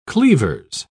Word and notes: cleavers: British English

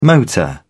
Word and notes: motor: British English

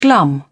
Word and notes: glum: British English

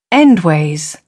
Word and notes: endways: British English

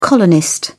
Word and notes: colonist: British English

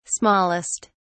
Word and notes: smallest: British English

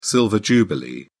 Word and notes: silver jubilee: British English

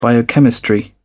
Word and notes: biochemistry: British English

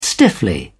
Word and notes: stiffly: British English

